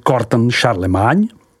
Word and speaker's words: Corton Charlemagne: Corton, vyslov Corton, Corton Charlemagne, vyslovuj Corton Charlemagne, Charlemagne, vyslovuj Charlemagne.